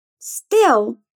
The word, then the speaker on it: still: Still, escrito S-T-I-L-L, significa ainda. Repeat. Still. Still steal, roubar.